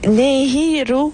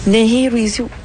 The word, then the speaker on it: Nehirawisi: Il dit Nehirawisi. Nehirawisi. – Nehirawisi. – Exactement. – Bon, alors, Nehirawisi, c'est comme ça que ça se prononce.